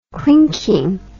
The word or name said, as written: clinking